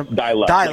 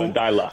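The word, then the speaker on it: Deila: Is it Ronnie? Is it Delia? How are we pronouncing it? I know that's been a... – Dylan. – Like okay, so... – Dylan. – Dylan. All right, so Ronnie Delia coming in.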